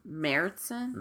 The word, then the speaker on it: Märzen: Märzen? – Märzen is how it's said in German.